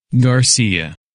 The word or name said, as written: Garcia